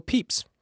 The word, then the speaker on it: Pepys: Okay, here's the spelling. P-E-P-Y-S. The actual pronunciation? Samuel Peeps.